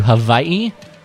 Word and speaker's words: Hawaii: Posted a video diary of sorts of our recent trip to Hawaii. That's how the locals say it, Scott.